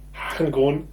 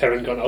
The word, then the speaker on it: Harengon: Ja, das ist, also die Herengon oder Harengon, aber ich würde das Herengon aussprechen, von Hare, also Hase.